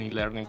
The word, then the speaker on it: e-learning: un e-learning, pues a estudiar o hacer algo relacionado con ello. E-learning, pues la I, aunque se pronuncia I, realmente es con E, como el email, que significa electronic o electrónico.